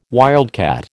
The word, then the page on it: wildcat: American English